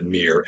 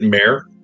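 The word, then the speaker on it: Mere: It's M-E-R-E. The Mirror of Dead Men. And from best I can tell, you pronounce it Mirror, M-E-E-R, is how it is meant to be. – Okay. – I would have pronounced it Mare because it's M-E-R-E, but from what I read in the lore, that is the correct pronunciation, but I would happily be corrected.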